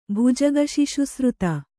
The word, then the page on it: ಭುಜಗಶಿಶುಸೃತ: bhujaga śiśusřta